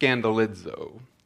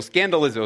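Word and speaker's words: scandalizo (σκανδαλίζω): And that word offended in the Greek, and I won't hang out on the Greek very long here, but it's actually pronounced scandalizo, and obviously has the word scandal in it.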